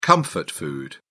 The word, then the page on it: comfort food: British English